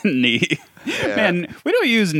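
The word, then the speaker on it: née: Is that how you say it? I always said nay in my head, like a horse.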